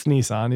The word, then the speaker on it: Nissan: He's like, what's Nissan? I was like, it's Nissan. He's like, that's what I thought. – I have not heard it pronounced like that since I stopped watching Top Gear after mm-hmm.